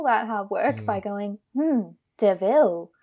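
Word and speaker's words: De Vil: It's like Horace has a car and it's like, it's a devil. And then Jasper's like, it's pronounced Deville. And the camera turns to Cruella and she goes, Deville.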